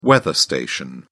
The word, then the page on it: weather station: British English